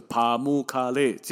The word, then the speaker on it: Pamukkale: 棉堡的土耳其文或者是英文的发音吧，哦，大概就是帕木卡列这样子的大约的发音。